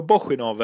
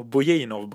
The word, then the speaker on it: Bojinov: Nej, det var ju Valerio Bosjinov eller Bojinov beroende på hur man vill uttala den här bulgariske mannens namn.